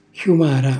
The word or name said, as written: xjumara